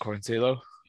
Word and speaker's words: Quarantillo: Obviously, Quarantillo's got a really good... That is how you say it, isn't it, Quarantillo? – Billy Quarantillo, yeah. Or Quarantillo. Depending on how Italian you want to get with it.